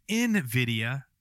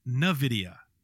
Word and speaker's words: NVIDIA: But I got to say it's pronounced NVIDIA, not NVIDIA.